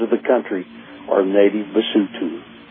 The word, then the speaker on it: Basotho: And then all the O's are pronounced ooh. – Ooh. And then the people, the people are called what? – Basotho, Basotho.